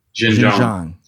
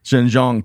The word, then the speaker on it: Xinjiang: particularly in the Xinjiang province. I think I got that right, too. – Xinjiang. – Close. Yeah. Gosh, I got the wrong emphasis on the wrong syllable.